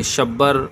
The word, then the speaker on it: شبر: ان اسماء کا تلفظ لغت کی مشہور کتاب قاموس میں ہے کہ شبر بقمہ کے وزن پر،